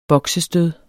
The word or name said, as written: boksestød